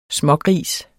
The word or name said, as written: smågris